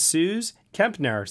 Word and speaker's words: Suze Kempner: And also joining us here for the first time ever as a guest Patreon subscriber is Suze Kempner.